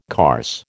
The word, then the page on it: carse: American English